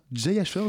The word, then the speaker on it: Jay Asher: Et une série qui est adaptée d'une euh, elle-même de nouvelles écrites par Jay Asher, je prononce bien Oui, tout à fait.